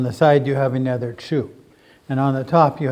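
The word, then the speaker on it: Chu (厨): So, we have the character for kitchen. It's pronounced Chu.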